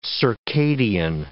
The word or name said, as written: circadian